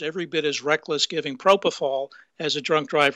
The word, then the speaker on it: propofol: On the propofol, can never pronounce that. – Propofol, yes.